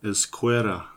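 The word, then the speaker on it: Ezquerra: So this movie was written by, obviously we have the credits of the original Judge Dredd creators, John Wagner and Carlos Esquire. Just like, not sure how you pronounce that one. – Yo, Esquera. – Esquera, yeah. There we go.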